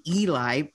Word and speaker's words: Eli: I have never seen Eli spelled E-L-I pronounced Ellie ever in my life, but it happened to me consistently.